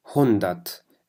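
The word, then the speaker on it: hundert: المية بكل بساطة اسمها هندت، الآية تلفظ لفظ خفيف.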